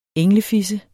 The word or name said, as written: englefisse